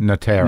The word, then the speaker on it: Notaro: How do you say her name? – Notaro. – Notaro, I'm sorry.